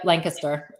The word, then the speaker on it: Lancaster: Yep, Lancaster.